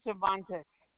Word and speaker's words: Cervantes: Martha Cervantes.